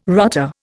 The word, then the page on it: rotter: Indian